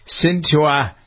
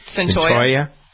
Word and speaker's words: Cyntoia: Cyntoia Brown. Is that how you pronounce her name? C-Y-N-T-O-I-A? – Cyntoia. – Cyntoia. – Cyntoia, yeah.